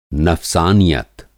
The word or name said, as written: नफ़्सानियत